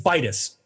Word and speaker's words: Vitus: Or I've been saying it wrong this whole time. It's St. Vitus. My God, like vital.